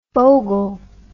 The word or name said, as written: fogle